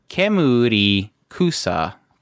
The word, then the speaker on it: Kemurikusa: Moving on, we have Kemurikusa. This one was streaming on Amazon, and that is Kemurikusa, for those who cannot understand my broken Japanese pronunciation of a very long name.